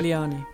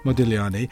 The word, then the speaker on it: Modigliani: You don't pronounce the G, I believe. Is that right? How would you say it? – Modigliani. – Modigliani.